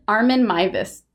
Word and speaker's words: Armin Meiwes: Armin Mivus. A-R-M-I-N. And it's spelled weird. It's M-E-I-W-E-S.